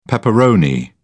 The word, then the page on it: pepperoni: British English